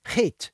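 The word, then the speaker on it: chait (חית): The way it's pronounced is chait. It's not H-A-T-E, but double H-A-Y-T, chait, or het sometimes.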